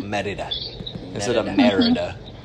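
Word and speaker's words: Mérida: So, being able to visit Brazil, and then you went to Merida, Mexico? – Uh-huh. – How do you say that? I'm saying it wrong. I'm so sure. – It's Merida. – Merida.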